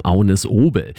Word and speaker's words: Agnes Obel: Ich spreche von Agnes Obel oder Agnes Obel, wie man sie dann auch auf Deutsch aussprechen kann. Aber auf Dänisch sagt man zu ihrem Namen Agnes Obel.